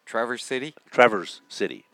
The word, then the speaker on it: Traverse City: Traverse City.